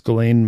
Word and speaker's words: Ghislaine: I didn't know that's how you pronounce her name. You drop the S. – Oh, way to go. – Yeah, so it's Ghislaine Maxwell and her role, and at least according to these girls, and it's just procuring.